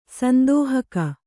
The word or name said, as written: ಸಂದೋಹಕ